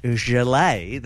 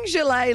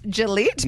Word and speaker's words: gilet: Are you saying gilet like Gillette? – No. – But fancy? – How do you spell it? – G-I-L-E-T. So almost like Gillette, but.